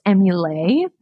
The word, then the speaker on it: Emulait: Emulait is spelled E-M-U-L-A-I-T. It's like emulate, you're emulating the breast, but it's a play on the French word for milk, which is lait. So it's pronounced emulate, but it's kind of written like emulate.